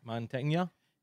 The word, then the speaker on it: Mantegna: Mantegna.